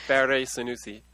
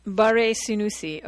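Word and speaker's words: Barré-Sinoussi: I think it's Barré Sanusi. – Barré Sanusi, okay.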